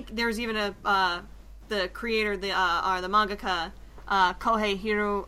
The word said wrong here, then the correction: Horikoshi: Forgive me, I'm going to butcher this name. It's Kohei. Let me see. Kohei Horikoshi.